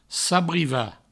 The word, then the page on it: sabrivar: sabriva